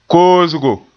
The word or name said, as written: koosgo